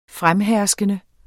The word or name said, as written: fremherskende